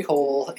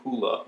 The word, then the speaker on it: Hole: I have seen on the interwebs that in Norway they do not pronounce the word hole that way. Presumably they have their own word for holes. But even as a last name, I guess this is a fairly common last name, it is pronounced differently, like Hula or something like that.